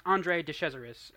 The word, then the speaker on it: Andre de Cesaris: But anyway, um, and uh, tip it's pronounced Andre de Cesaris, and I don't remember when we were pronouncing his name.